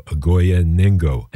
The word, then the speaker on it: Ogoya Nengo: And on this new album from them called Palagoma, they've teamed up with the 83-year-old Kenyan singer Ogoya Nengo. And her name is spelled O-G-O-Y-A, Ogoya, and then N-E-N-G-O, Ogoya Nengo.